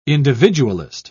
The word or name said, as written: individualist